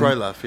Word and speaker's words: Krylov: Is that even how you pronounce his name? – Krylov, yeah.